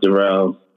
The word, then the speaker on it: Daryl: I go by Daryl. I know it's spelled Daryl, but. It's pronounced Daryl.